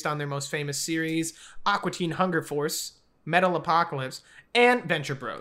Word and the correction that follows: Metalocalypse: I'm pretty sure that one's pronounced Metalocalypse.